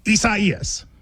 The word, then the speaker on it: Isaias: A lot of people, you know, prayers to everybody involved, but it is Isaias. – Is that how you say it, though? – You do.